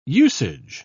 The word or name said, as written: usage